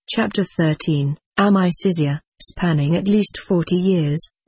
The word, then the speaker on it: Amicizia: In Italy, those most deeply involved named the group Amicizia, pronounced Amicizia, friendship.